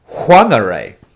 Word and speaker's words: Whangarei: And so every other, Māori word that has a WH is always fa, like an F sound. Whangarei, like you said. Whereas the local iwi, they actually pronounce it Whangarei, like with a W.